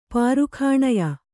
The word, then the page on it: ಪಾರುಖಾಣೆಯ: pārukhāṇeya